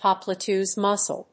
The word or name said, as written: popliteus muscle